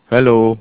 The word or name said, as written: Hallo